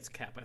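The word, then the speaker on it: Koepp: also was written by someone named Jonathan Taylor Tidor and David Kep or Coep or however you say his name, who famously wrote. – I've always said it Kep, but I guess I have absolutely no confirmation on how to say his name, but yeah, I think it's Kep. I think I've heard it's Kep.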